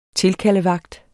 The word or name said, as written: tilkaldevagt